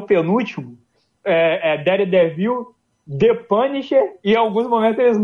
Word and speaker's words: The Punisher: Não é The Punisher. É o The Punisher.